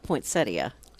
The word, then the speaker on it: poinsettia: Because I knew it was spelled poinsettia. – Yeah, and it should be pronounced that way.